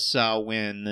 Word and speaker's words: Samhain: I looked it up on Wikipedia, which is always right about everything, and it confirms that Samhain is the correct pronunciation. – It's not Samhain. – No, it's not Samhain, nor is it Shaman somehow, as I obtusely guessed.